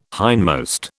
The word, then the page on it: hindmost: British English